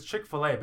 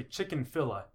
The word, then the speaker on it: Chick-fil-A: It's because it's Chick-fil-A, but you say chicken filla. – Yeah. – So you add a syllable, and then you mispronounce the second two.